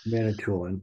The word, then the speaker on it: Manitoulin: Manitoulin.